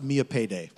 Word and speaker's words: Millipede: It's spelled Millipede, but it's pronounced Mia Payday.